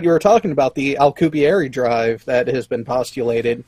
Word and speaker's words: Alcubierre: I say Alcubierre, but... – Alcubierre. – How do you... – Alcubierre. – Yeah. Okay, I'm gonna say it from now that way from now. Alcubierre.